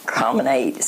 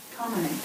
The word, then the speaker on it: comminate: Cominate, C-O-M-M-I-N-A-T-E. – Cominate. – I said it right.